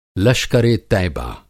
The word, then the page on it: लश्कर-ए-तैबा: Hindi